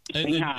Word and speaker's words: Singha: Singha.